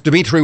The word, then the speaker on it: Dimitri: My profile page is Dimitri with three I's, D-I-M-I-T-R-I.